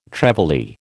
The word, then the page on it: trevally: American English